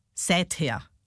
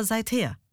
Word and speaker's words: seither: Wie viele sagen seither? Und das heißt aber seither.